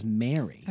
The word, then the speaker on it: Meeri: And it's spelled M-E-E-R-I. – Mm-hmm. – But they say, she flat out says Mary.